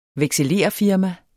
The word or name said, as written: vekselererfirma